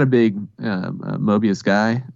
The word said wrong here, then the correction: Morbius: Morbius, thank you.